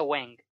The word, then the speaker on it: Wang: Recordemos que el señor Hume, en inglés es señor Wang, se pronuncia Wang, no señor Hume.